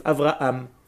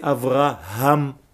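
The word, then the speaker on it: Avraham (אברהם): Et donc, c'est faire attention, surtout quand on vient de France ou d'ailleurs, de prononcer la lettre « he » qui est difficile pour les francophones, qui prononcent généralement le « he » comme un « aleph », « Avraham ». Ce n'est pas « Avraham », c'est « Avraham ».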